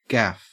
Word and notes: gaff: Australian English

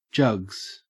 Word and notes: jugs: Australian English

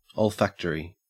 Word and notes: olfactory: Australian English

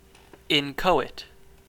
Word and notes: inchoate: American English

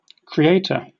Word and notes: creator: British English

